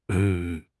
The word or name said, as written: en5